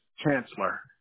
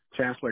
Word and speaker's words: Chancellor: And Chance, your first name is spelled C-H-A-N-C-E, right? – Yes, C-H-A-N-C-E-L-O-R. My name is Chancellor. Chancellor Kane.